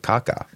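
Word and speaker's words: Kaká: And that's really how you pronounce it, Kaka.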